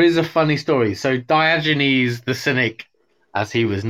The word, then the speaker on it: Diogenes: So Diogenes. – Yeah, absolutely. Good pronunciation.